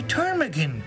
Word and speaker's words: ptarmigan: P-T-A-R-M-I-G-A-N. – The P is silent, huh? – Yes.